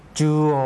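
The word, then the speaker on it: จู โ อ (中央): จูโอ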